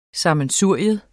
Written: SAMMENSURIER

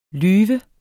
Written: lyv

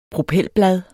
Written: propelblad

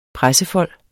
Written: pressefold

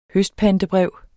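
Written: HØSTPANTEBREV

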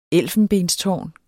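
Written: ELFENBENSTÅRNET

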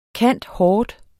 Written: cand.hort.

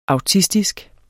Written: autistisk

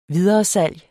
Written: videresalg

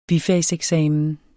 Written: bifag

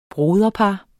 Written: broderpar

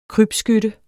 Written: krybskytte

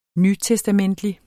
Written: nytestamentlig

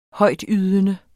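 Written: Højtydende

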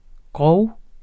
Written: grov

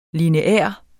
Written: LINEAR

